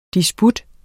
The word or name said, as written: disput